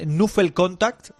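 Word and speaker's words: knuffelcontact: Pues a ver si lo digo bien, es Nuffel Contact, así... – Lo has dicho perfectamente.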